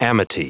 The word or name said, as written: amity